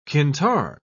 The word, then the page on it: qintar: British English